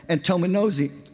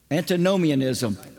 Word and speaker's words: Antinomianism: And one of the things that John was coming against was the false teaching of a heresy called Antinomianism. Oh, I messed that one up. And how do you say it? Antinomianism. I got it.